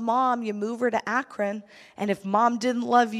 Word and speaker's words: Akron: Akron, is that how you say it? Akron? Akron.